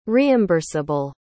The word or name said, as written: reimburseable